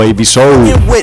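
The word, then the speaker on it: Ab-Soul: Los fraseos siempre recomendables de un MC californiano llamado AB Soul o Ab Soul, como lo quieras pronunciar.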